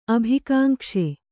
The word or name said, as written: ಅಭಿಕಾಂಕ್ಷೆ